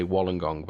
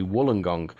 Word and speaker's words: Wollongong: I was fortunate enough to go to, I have to say it right because in England we say Wollongong, but actually they say Wollongong.